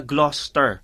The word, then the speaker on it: Gloucester: Yung pag pin, pinronounce mo, ah, pag binasa mo siya parang Gloucester. Pero yung pala, Gloucester.